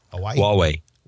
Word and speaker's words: Huawei: How do you say that? Hawaii? – Huawei. – Huawei? H-U-A-W-E-I. How do you get Huawei out of that? – Huawei. H-U-A. – I guess Way. – Yeah, Huawei.